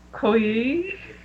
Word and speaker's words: Koye: And they told us that to say their name is you pronounce the O like in dog and the A E I as in yay, so it's like Koye. – Koye, okay.